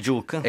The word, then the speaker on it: Juke: Il Juke. – E questa è la pronuncia giusta? – Sì, credo che sia questa.